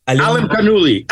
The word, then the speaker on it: Alimkhanuly: Alim, Kanouli.